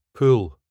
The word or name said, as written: pool